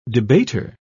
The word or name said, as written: debater